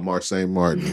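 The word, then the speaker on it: Marsai Martin: Oh, Marseille Martin.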